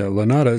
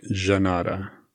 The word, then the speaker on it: Llanada: The original name Sarah gave the house was La Nata Villa. I probably said it wrong. – Yeah, Lanada, it's spelled L-L-A-N-A-D-A. And from what I could find, it sounds like Janata, mm-hmm.